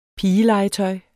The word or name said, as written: pigelegetøj